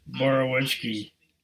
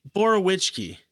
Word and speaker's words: Borowiecki: Like, like I I actually think um, but how the hell do you say his name? Bacheski? Borowiecki. Borowiecki.